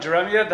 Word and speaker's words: jeremiad: Jeremiad, that's how you pronounce it? That's what happens when you read and don't say. So if it's Jeremiad, I stand corrected, then the same thing.